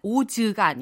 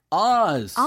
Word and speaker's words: Oz: 원어민 발음으로는 오즈가 아니라 Oz.